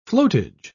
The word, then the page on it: flotage: British English